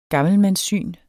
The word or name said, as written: gammelmandssyn